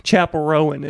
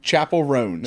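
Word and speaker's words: Chappell Roan: I just like a week ago discovered who, like, I'm gonna say the name wrong, Chapel Rowan is it Chapel Roan? – I thought it'd be Chappelle Roan, perhaps. Is it Chapel Rowan? – Chapel Roan. – Chapel Roan. – Yes.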